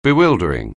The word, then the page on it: bewildering: anglais